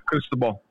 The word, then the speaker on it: Cristobal: Is it crystal ball? this is how they spell it. CRISTOBAL. – Yeah, crystal ball.